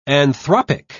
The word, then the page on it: anthropic: British English